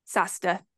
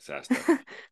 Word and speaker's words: SAHSDA: How do you say that quickly? – SASTA is how SASTA. I say it. – That's how you say it, SASTA.